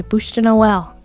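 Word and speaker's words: bûche de Noël: And Scott put a Bush to Noel.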